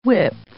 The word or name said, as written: whip